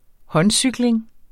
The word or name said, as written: håndcykling